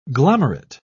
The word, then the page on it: glomerate: British English